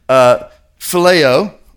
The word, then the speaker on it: Phileo: How do you say it? Phileo. Yeah, he's from the north.